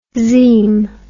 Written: xeme

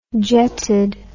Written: jetted

